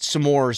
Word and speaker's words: s'mores: It's supposed to say, you're supposed to pronounce it s'more because it's like some more.